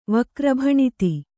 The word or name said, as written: ವಕ್ರಭಣಿತಿ